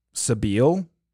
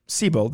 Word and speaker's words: Sebile: It's spelled S-E-B-I-L-E. So Sabile? – Sabile? – Sabile sounds terrible, though. – Sabile does not sound right. Yeah. – Sebel? – Third option. – Sebel. – Yeah. – Okay. Good job. Sebel. I think one of the biggest details that came out of... – Is that how you pronounce it? – Did you hear the two options that I just threw out there? Wasn't Sebel? I think Sebel's right.